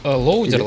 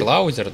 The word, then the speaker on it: louder: Лоудер, лаудер, да? – Лаудер. – Ну, лоудер пишется, на лаудер произносится по-английски.